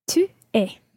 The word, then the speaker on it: tu es: Tu es.